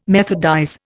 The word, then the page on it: methodize: Indian